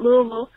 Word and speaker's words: Louisville: And that's right, that's how you pronounce it, Louisville, the land of bourbon.